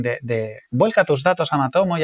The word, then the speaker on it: Matomo: Aquí hay alguien que se ha puesto mucho las pilas, que son los de eh, Matomo, ¿lo digo bien? Sí.